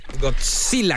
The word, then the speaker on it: Godzilla: ¿Godzilla con T? – Godzilla. – Tienes que aprender a pronunciar las cosas.